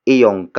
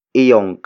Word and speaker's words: iyong: When used as a possession marker, one example is iyong kape. Iyong kape.